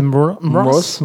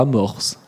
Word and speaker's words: Mross: M. Mross, c'est Mross. – Mross. Pas Morse. – Mross. Non, c'est Mross.